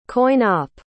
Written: coin-op

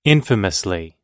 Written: infamously

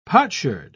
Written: potsherd

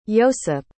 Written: yosyp

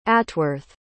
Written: atworth

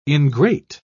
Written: ingrate

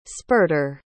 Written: spurter

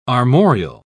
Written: armorial

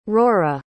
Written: ruaidhra

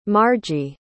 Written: margie

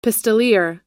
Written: pistoleer

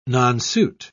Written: nonsuit

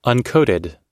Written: uncoated

